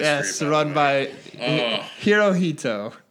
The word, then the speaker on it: Hirohito: It's Horihoto. – Horihoto? – No, Hirohito.